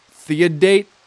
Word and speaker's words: Theodate: Now, I literally thought it was Theodora. No, her name is Theodate. That's not a mistypo. It's not like some weird pronunciation that I'm doing. It is literally Theodate Page Price.